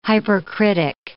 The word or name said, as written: hypercritic